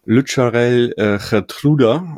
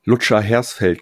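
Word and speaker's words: Lutsharel Geertruida: Also Lucharel Gertruda aus den Niederlanden von Feyenoord. Wie würdest du ihn aussprechen? – Ja, ich würde es genauso machen.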